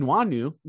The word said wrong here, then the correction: Nwangwu: Nwangwu. That's Nwangwu.